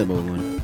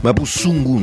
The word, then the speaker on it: Mapuzungun: ¿Cómo se pronuncia Mapudungun? No se pronuncia Mapudungun. – Mapuzungun. – Mapuzungun. Mapuzungun. – Mapuzungun. – Así, Mapuzungun.